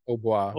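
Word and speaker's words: hautbois: Obois.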